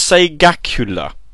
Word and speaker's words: Segacula: It's like Sega, and it's a spectacular, but rather than call it Sega Spectacular, because that would be even worse, it's a Segacula.